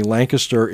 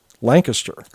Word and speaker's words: Lancaster: Like, we are in Lancaster, Pennsylvania, and I thought that we were the only Lancaster in the country that pronounced it Lancaster. – Okay. – As opposed to Lancaster. No, out in Ohio, they call it Lancaster, and I was very surprised.